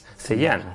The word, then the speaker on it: Zhejiang: Zhejiang, sí, bueno, la Universidad de Zhejiang.